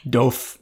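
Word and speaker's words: DOF: I'll put a link to them in the show notes because I know it's weird to describe them, but they're called Carrots Doff glasses, D-O-F. I don't know if I'm saying that right. – Doff.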